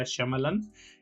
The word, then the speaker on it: Shyamalan: no me acuerdo bien cómo se pronuncia, pero si no estoy mal, se pronuncia Shyamalan, el, apellido de este director medio infame a veces y medio célebre otras.